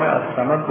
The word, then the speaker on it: الصمد: لام الصمد درست تلفظ نمیشه، شنیده نمیشه، اما کسی که نگاه بکنه روی خط الصمد که نوشته شده مکتوبش لامش رو میگیره.